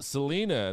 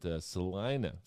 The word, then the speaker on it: Salina: I called it Salina, and they corrected me. They were very insulted that I pronounced it like an out-of-towner, being the out-of-towner that I was. But Salina.